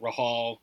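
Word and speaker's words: Rahall: Like, I pronounce it Rahal. My brother pronounces it Rahal. My mom pronounces it Rahal. My dad pronounces it Rahal. So there's not a wrong way.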